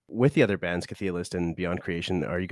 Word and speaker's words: Chthe'ilist: I mean, a lot of people asked me in the past, like, how do you pronounce it? I'm like, Chthe’ilist.